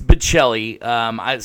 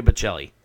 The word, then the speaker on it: Bocelli: It's Bocelli. Sometimes I make the mistake and say Bocelli, but it's actually Andre Bocelli.